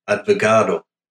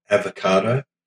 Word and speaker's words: advogado: and his name was an advogado, not avocado, advogado. And hopefully I got the pronunciation right.